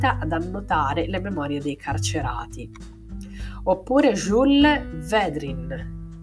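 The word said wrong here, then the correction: Védrines: Vedrines, si scrive Vedrines,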